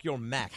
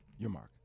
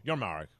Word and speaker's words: Yormark: Is it Yormark, Yormack? How do you pronounce it? – Yormark. – Yormark, right? But people rush through the R. Yormark.